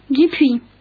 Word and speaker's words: Dupuis: Dupuis. – Spell it, please. – D-U-P-U-I-S.